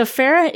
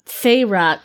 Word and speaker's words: Feyre: So Farah is our lead. – Feyre.